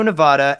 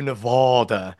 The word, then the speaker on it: Nevada: So the proper way to say it is Nevada, not Nevada.